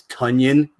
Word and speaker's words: Tonyan: Tunyon, who I actually like it as Tunyon. It sounds like how we would have pronounced it wrong initially, and he would tell us it was Tunyon.